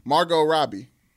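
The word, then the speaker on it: Margot Robbie: It's a T silent T. Margo Robbie.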